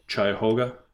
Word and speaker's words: Cuyahoga: Cuyahoga, that you just talked about.